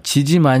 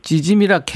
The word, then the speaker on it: 찌짐: 0059님이 오빠 지짐 아니고 찌짐이라 해야지요 하셨네. 찌짐이라 그래요? 네, 보통 경상도 발음은 센 발음이라.